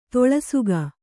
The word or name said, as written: ತೊಳಸುಗ